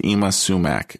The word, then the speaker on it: Ima Sumac: I did look up the pronunciation. It is pronounced Ima Sumac, okay.